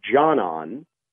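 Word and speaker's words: Džanan: Yeah, I mean, just early, if we're just going to do some early pronunciation exercises, it's Jonon, so that's the first name.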